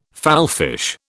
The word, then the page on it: fallfish: British English